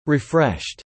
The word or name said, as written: refreshed